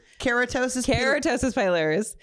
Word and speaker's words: keratosis pilaris: KP, ketosis pilipar. – Ketosis? – See, I got you. – You got me. – How do you say it? Do you remember? Keratosis pilaris. Keratosis pilaris.